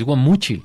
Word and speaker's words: Guamúchil: Es Guamúchil. – Si le pones acento en la I, sí se... – Pero lleva acento en la U. – Lleva acento en la U, Guamúchil.